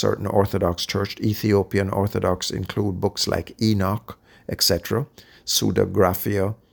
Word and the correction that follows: pseudepigrapha: if I'm pronouncing that right. Yeah, pseudepigrapha, I'm sorry.